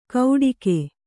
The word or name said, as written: ಗೌಡಿಕೆ